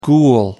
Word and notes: ghoul: American English

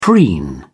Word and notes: preen: British English

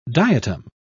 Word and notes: diatom: British English

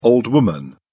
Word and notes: old woman: British English

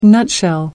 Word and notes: nutshell: American English

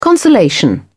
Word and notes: consolation: British English

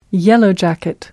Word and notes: yellow jacket: British English

